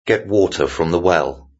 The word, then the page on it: get water from the well: British English